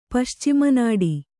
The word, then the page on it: ಪಶ್ಚಿಮನಾಡಿ: kannada